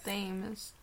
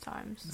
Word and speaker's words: Thames: A lot of people say Thames. But it's Times.